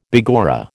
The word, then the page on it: begorra: American English